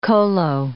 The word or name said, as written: kolo